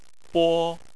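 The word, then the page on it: bo: tone 1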